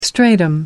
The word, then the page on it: stratum: American English